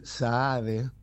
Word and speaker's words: Sahad: Raúl Saade, está bien pronunciado porque es S-A-H-A-D, sí.